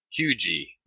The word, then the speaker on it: Huger: And I've been pronouncing it Huger, but that's how you stand out as not being a local, because the locals call that Hugie. – Wait, how they say Hugie and not Huger? – There's no R on it. It's huge E. For the locals, there's no R. Even though it's spelt with an R, they don't pronounce the R.